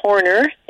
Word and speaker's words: Horner: Then uh, Christine is spelled C H R I S T I N E, and my last name is Horner H O R N E R and then it's just dot com.